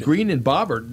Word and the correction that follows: Boebert: it's not Bobbert, it's Boebert. – How do you say that name? Boebert. – Yep.